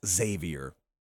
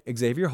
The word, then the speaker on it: Xavier: I go really, really hard on the X, and it's just Xavier. It's not Xavier. But I had a kid, Xavier Hall,